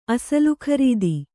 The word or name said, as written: ಅಸಲುಖರೀದಿ